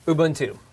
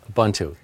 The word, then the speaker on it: Ubuntu: And by the way, there's a correct way to pronounce it. You all listen up. – Ubuntu. – Ubuntu. Yes, I was coached.